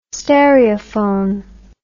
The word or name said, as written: stereophone